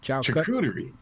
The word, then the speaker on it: charcuterie: How do you say it? – Charcuterie. – Charcuterie? – I think, yeah, security boards.